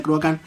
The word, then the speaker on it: Cruachan: Cruacán o Cruachan se escribe, pero se pronuncia Cruacán.